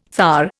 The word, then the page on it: thar: Indian